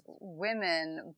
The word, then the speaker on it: WOMXN: Okay, "We aim to marry the idea of performance activewear with street style for the resilient, mindful, and real, I don't actually know how you pronounce this word. It's WOMXN. Okay. – Oh, it's women, but meant to include the letter X.